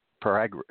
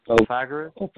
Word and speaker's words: Pythagoras: Do you know how to say that? P.Y. It's an ancient Greek anyway. – How do you spell it? – P.Y.T.H.A.G.O.R.A.S. Pythagoras. – Pythagoras. – Pythagoras. Mm-hmm. Yes.